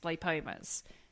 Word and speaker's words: lipoma: And it was all basically, it was either cysts or they were lipomas. Lipomas. Is that how you pronounce it, Kate? – Yeah, lipoma, yep.